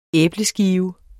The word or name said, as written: æbleskive